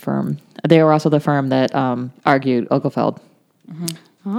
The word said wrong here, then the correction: Obergefell: I don't think that's how that's pronounced. – Obergefell, I think it is. – Yeah. – Obergefell.